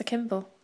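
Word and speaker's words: Kimbell: At Vanessa Kimball. – Which is quite easy to remember because it's your... – They spell it wrong. It's K-I-M-B-E-L-L as in Ding Dong Bell.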